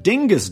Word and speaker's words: Dyngus: On Monday, 50,000 people searched up Dingus Day. That's spelled D-Y-N-G-U-S, if you want to look this up yourself.